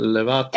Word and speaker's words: Lovato: And um, confident with Demi Lutavo. Is that how you say it? – Ah, Lovato. Uh, Levato. – Yeah, Levato. Levato. There you go.